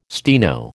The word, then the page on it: steno: American English